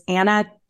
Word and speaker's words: Anna: Anna Rahe, R-A-H-E. It's Anna, two N's, A-N-N-A.